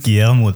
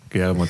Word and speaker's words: Guillermo: Ähm, vielleicht kannst du kurz den Vornamen aussprechen für mich. Es ist Guillermo del Toro. Guillermo del Toro, sehr schön.